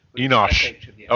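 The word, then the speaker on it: Enosh (אנוש): they were called Enochs, which is frail humans, Ish. They were not Ishes. They were not Ishes. They were Enochs. Or however Enosh, you would pronounce Enosh. Okay, Enosh. Thank you.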